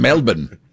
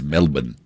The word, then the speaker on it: Melbourne: It's Melbourne. – Melbourne? – Melbourne. – Melbourne. – Melbourne. – Oh, Melbourne. – Yeah, it's Melbourne, but you have to say it like you're like from Jason there. Bourne.